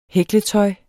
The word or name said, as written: hækletøj